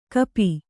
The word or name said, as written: ಕಪಿ